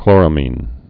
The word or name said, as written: chloramines